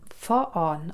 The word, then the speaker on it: fan: Allerdings wird eigentlich im Schwedischen nicht fürfahren gesagt, also F-Ö-R, sondern eigentlich für Also F-Y geschrieben und für ausgesprochen. Und dieses vorn mm, wird F-A-N geschrieben, für die unter uns, die Worte gerne ähm, mit dem Auge lernen, lesend. Ähm, und zwar wird es dann for on ausgesprochen.